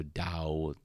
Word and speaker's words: Dao: it's kind of pronounced with like a, a, a bit of a d so dao dao de jing um, okay.